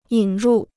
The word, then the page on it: 引入: yǐn rù